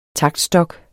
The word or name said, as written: TAKTSTOKKE